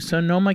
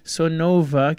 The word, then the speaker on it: Sonoma: Yoav Saralbo, our guest today, talking about his trip to Sonoma County, not to be confused with Sonova County, which I constantly mispronounce it.